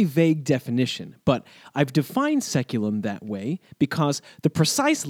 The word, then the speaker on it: saeculum: I'll be using that word quite a bit in this podcast, so from here on out, I'll be sticking with the anglicized pronunciation seculum.